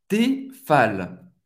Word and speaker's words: Tefal: Tefal. O E tem o som do E. E vous vai pronunciar o L No final, Tefal, Tefal em francês, Tefal, Tefal.